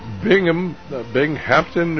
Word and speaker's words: Binghamton: And we're about to go to Binghamton, New York.